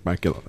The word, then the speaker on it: Bakula: I think every so often, you know, if Scott Bakula, and I have a terrible feeling it actually is pronounced Bakula. Like it shouldn't be.